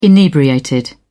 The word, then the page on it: inebriated: British English